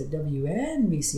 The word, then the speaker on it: WNBC: One of the more I guess memorable moments was he's like, when you're introducing the radio station, you have to say WNBC. You have to put the emphasis on the NBC.